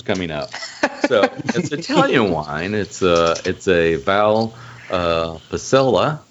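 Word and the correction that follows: Valpolicella: I believe is the way you pronounce that. Sure. – Policella. – And it's from Villa Mafia. – Maffei. M-A-F-F-E-I.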